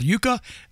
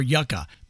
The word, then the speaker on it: Yuka: It's either called Yuka or Yuka, but it's spelled Y-U-K-A.